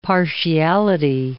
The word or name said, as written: partiality